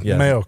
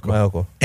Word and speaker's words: Maiocco: Is that how you say it? – Yeah. Maiocco. – Maiocco.